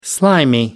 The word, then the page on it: slimy: American English